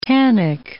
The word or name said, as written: tannic